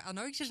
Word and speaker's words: Anoixis: En bij mij aan tafel zit Stichting Anoixis. Zeg ik het goed? – Anoixis had je goed.